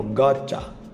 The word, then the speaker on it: gotcha: Lá nos Estados Unidos, quando ele é pronunciado, geralmente ele vem com uma contração, que o americano gosta de fazer, que é o gotcha, ou gotcha eu li como se fosse lendo em português, tá?